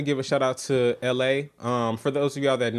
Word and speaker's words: Elhae: Yes, it's spelled ELHAE. It's funny that his name is LA, but he has a song out with somebody called I Am.